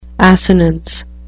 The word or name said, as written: assonance